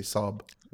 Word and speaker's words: Saab: Is that how you pronounce it? Sab? – Sab, yeah. – Sab, yeah. – S-A-A-B. – Where them, they say, I think they say Sob. – Yeah. – With their accent there, anyway.